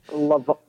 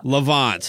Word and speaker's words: Levant: Am I saying that right? – Levant. – Levant. Thank you for that.